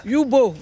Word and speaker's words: Yubo: Et leur truc là, Hugo, c'est Hugo ? C'est quoi ? Dis-moi, dépêche-toi. Yubo, voilà, Yubo.